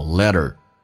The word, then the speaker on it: let her: let 跟 her 有一个击穿， h 音不发音，用 t 跟 er 形成个连音，叫做 let her。